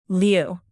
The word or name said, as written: lieu